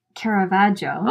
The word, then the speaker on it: Caravaggio: I say Caravaggio. – Oh. – Which some people enunciate the I at the end, okay.